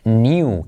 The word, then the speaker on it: knew: All right, siguiente on the list, next on the list, new, que es el pasado de no, ¿vale? Pero también es acá, no se pronuncia. New, o también se puede decir new, All right, New, tanto new como new.